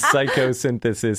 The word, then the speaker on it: Psychosynthesis: The movie is Psychosynthesis. So it's like psycho and synthesis, one word though.